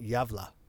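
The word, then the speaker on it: Gävle: It's spelled Gavle, but it's pronounced Yavla.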